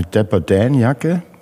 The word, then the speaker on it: Dapper Dan: wie Gino für die Ausstellung sogar eine Dapper Dan-Jacke, habe ich es richtig ausgesprochen? – Absolut richtig.